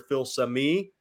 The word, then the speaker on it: Filsaime: They could take another high school safety, Xavier Filsaime. They could also. – I'm glad you're saying that name. So now I know how to pronounce it correctly because we've been butchering that joker for 2 weeks.